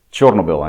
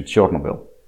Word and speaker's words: Chernobyl: Chernobyl, actually, that's the correct pronunciation, Chernobyl.